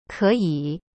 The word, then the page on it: 可以: keyi